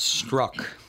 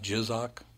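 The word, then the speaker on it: Strzok: Apparently, it's pronounced struck. Oh, it's pronounced struck? Somehow. It's not jizzock or whatever I said.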